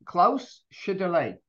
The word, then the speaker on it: Klaus Schlichtherle: Today, I am delighted to have the CEO of Infinigate, Klaus Schlichtherle. Klaus, how did I do? I do okay with that? – Yeah, the pronunciation is pretty good, actually. Schlichterle, Klaus, sounds very close to what you said.